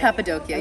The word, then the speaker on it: Cappadocia: And I was planning to do Cappadocia. – Mm-hmm. – Am I saying that right? – I don't know. I call it Cappadocia. But Cappadocia. Okay. You're right. That's the right way.